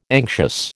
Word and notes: anxious: American English

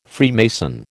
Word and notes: Freemason: American English

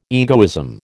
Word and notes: egoism: American English

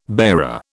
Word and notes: bearer: British English

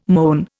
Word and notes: moan: Indian

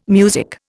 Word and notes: music: Indian

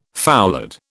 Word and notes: foulard: British English

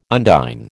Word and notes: undine: American English

